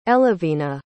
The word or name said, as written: elivina